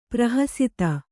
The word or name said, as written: ಪರಿಹಸಿತ